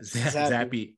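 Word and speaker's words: Zappe: Zappy.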